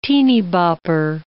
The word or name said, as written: teenybopper